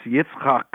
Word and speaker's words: Yitzchok: That's Yitzchok.